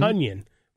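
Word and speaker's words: Tonyan: Tunyon rhymes with Funyon.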